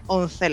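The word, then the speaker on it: Onze: En esta oportunidad quise mencionar y quedé encantado con este grupo, se llama Once, con Z, sería Once la pronunciación correcta.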